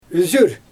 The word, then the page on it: yzyr: Xyzyl